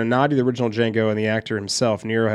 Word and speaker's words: Django: Fox's character drinks and talks with a man, and that man next to him, tells him, "My name is Django, the D is silent."